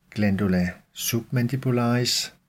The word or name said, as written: gl. submandibularis